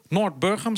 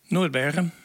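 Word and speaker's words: Noardburgum: Vandaag zenden we live uit vanuit het dorp Noord-Burgum, zeg ik dat goed? – Dat zeg je helemaal goed. Dat is... – In het Nederlands klinkt het net anders. – Noord-Burgum. – Noord-Burgum, inderdaad.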